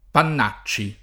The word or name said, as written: Pannacci